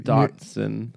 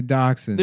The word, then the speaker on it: dachshund: That dash hound. – Is that what they're called? – Yeah. – Dachshund. – They're dachshund. – There's nothing called a dash hound.